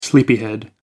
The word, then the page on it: sleepyhead: American English